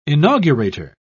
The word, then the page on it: inaugurator: British English